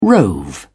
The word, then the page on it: rove: British English